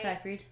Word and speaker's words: Seyfried: It's really funny because in Red Riding Hood with Amanda Seyfried, whatever the fuck her last name is, I can never say it right. – Seyfried.